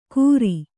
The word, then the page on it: ಕುರಿ: kannada